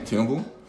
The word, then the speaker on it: Tinubu: Is that how you say his name? Tinubu? Yeah, from things I've heard about him, I don't think Nigerians have, you know, clocked that he's the president.